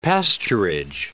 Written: pasturage

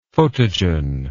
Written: photogen

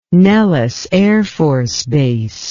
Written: Nellis Air Force Base

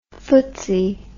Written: ftse

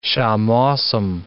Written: shammosim

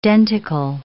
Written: denticle

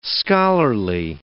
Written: scholarly

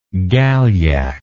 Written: galyak

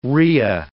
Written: rhea

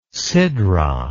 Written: sidra

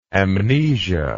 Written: amnestic

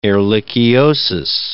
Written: ehrlichiosis

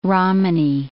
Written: Romany

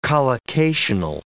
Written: collocational

